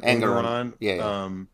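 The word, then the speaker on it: Engoron: Judge Arthur Ergeron also ordered Trump to pay. Is that right? – Angeron, I don't know how to say it either. Angeron, Engeron also ordered Trump to pay a fine of $10,000 each day until he complies.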